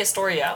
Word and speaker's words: Astoria: Is it really Astoria? – It's Astoria. No, Astoria. – I just said it wrong.